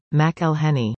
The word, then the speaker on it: McElhenney: On Friday, April 14, Reynolds revealed this year's present, a catchy song teaching the world how to pronounce his buddy's last name correctly. The tune lists all the wrong ways to pronounce McElhenney, and the correct way, McElhenney.